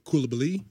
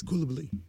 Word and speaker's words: Koulibaly: And I assigned him to Koulibaly, which is how you should pronounce it. – It'll be Koulibaly. It's fine. – It's Koulibaly. That's how you're supposed to pronounce it.